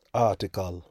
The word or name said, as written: aartical